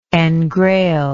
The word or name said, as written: engrail